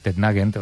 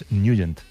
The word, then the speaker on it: Nugent: Hombre, siempre lo hemos llamado en el barrio Tednagent o Tednagent. – Claro, ¿verdad? Yo era de Tednagent. – Pero la pronunciación correcta es Nugent.